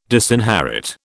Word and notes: disinherit: British English

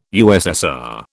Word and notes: USSR: British English

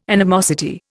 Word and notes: animosity: Indian

